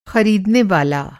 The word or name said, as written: ख़रीदनेवाला